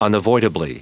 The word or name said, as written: unavoidably